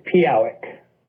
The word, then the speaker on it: Pehowic: So your last name is pronounced Pehowic, right? I want to. – No, it's Pehowic.